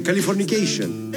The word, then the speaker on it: Californication: Muy bien, Californication.